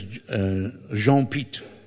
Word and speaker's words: Pitre: His surname was spelled P-I-T-R-E, but I don't think you pronounce the R-E. His name was Jean-Pete, you know?